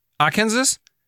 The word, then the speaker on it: Arkansas: How do you pronounce that again? Arkansas? – You say it like that.